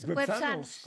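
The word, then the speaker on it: güepsanos: No, lo dijo bien Andrés. Websanos.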